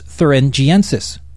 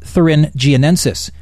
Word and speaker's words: thuringiensis: In a bacteria that's normally found in soil called Bacillus thuringiensis. – BT is instead pronounced Bacillus thuringiensis, not thuringiensis.